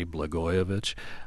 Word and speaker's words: Blagojevich: He walked around City Hall explaining to all the reporters there at that time, hey, this is how you say Blagojevich.